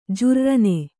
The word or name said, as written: ಜರ್ರನೆ